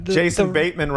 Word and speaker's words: Bateman: Jason Bateman rhymes with Jason Greatman.